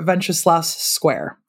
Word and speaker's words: Wenceslas Square: Vokzalsky Namesti or Wenceslas Square.